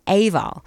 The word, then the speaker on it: Eabhal: and together they are Eabhal, but that's spelled E-A-B-H-A-L, and the single is a traditional Gaelic song which they reimagined with original arrangements and compositions as well.